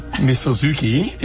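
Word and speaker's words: misozuke: The dish, tofu mizoksuki. – Misozuki. – Misozuki is a miso-cured tofu, hence the pronunciation misozuki.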